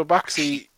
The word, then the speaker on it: Bakshi: And yeah, Ralph Bakshi, how do you say it? Ralph Bakshi? – Bakshi. – Baxi. So Bakshi.